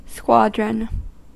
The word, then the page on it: squadron: /ˈskwɒd.ɹən/